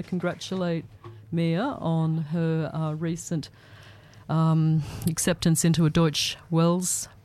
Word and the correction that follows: Deutsche Welle: program in, or Deutsche Welle, I think that's the right way to say it, isn't it, Mia? – Deutsche Welle. – Deutsche Welle, thank you.